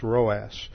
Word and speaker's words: Rhoas: or was also called Roas. That's spelled R-H-O-A-S, Roas.